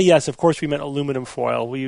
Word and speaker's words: aluminum: You know, it's two more syllables. – No, I think it's more than that. – Three. – It's three more. – Aluminum. – Close enough. Whatever. – Yeah, four. – Three or four if you say it wrong, like the English did.